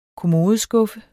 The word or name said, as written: kommodeskuffe